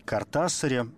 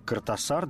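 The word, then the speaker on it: Кортасар: Главное, наверное, в Картасаре – это две вещи. Многие до сих пор произносят его фамилию как Картасар. Давайте уже как-то в очередной раз окончательно решим, что он все Хулио Картасар, ничего мы с этим делать не будем.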